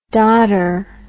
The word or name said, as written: dotter